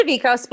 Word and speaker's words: Davekos: It's Davikos, but I will spell that variation.